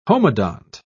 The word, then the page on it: homodont: British English